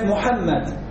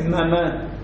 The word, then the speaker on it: Muhammad: El nombre del profeta es Muhammad. Muhammad, si quiere decir Muhammad. No es mamá.